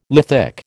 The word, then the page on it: lithic: American English